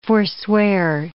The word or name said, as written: forswear